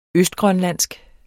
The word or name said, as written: østgrønlandsk